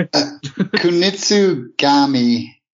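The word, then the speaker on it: Kunitsu-Gami: Kunitsu Gami, Path of the Goddess.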